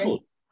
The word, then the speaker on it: Trumble: Am I saying that correctly? – Trumble.